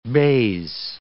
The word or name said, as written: baize